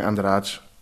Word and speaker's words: Andrade: Aber wir Cariocas aus Rio würden sagen Andrade oder Andrade irgend so. – Ja, klingt gut, wir nehmen das. – Mit viel G und I.